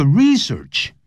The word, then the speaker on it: research: In order to research the origins of the English language，为了要研究这个 research，名词、动词都念成 research。